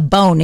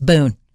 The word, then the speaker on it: Boone: And it's not a Bone either. It's Boone.